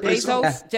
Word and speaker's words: Bezos: Yeah, Jeff Bezos.